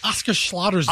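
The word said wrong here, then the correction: Osgood-Schlatter: disease. – Osgood Slaughter's disease.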